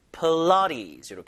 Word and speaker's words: Pilates: 자, 필라테스 발음이 필라테스, 필라테스가 아니라 이 강세가 중간에 있거든요. 사람 이름입니다, 이게요. 필라테스, 푸처럼 주다가 라에 강세 주세요. 필라테스, 이렇게.